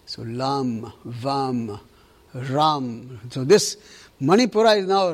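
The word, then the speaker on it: Ram (रं): They are written as, you know, in Sanskrit we write it as R-A-M, for example, but it's pronounced almost like R-U-M. So it's pronounced not Ram, it's pronounced as Ram, R-U-M, Ram, okay?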